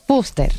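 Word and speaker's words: Fúster: Sí, es con acento en la U y R al final. – Fuster. – Fuster.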